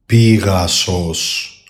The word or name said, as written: PEGASOS